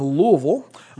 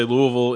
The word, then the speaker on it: Louisville: I started playing D&D in 1982 in Louisville, and that's the proper pronunciation, by the way. – Yeah. Here in the Midwest, you have to say Louisville in as few syllables as possible. – Louisville.